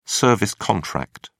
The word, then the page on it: service contract: British English